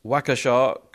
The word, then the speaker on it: Waukesha: He said, you did pronounce Waukesha correctly.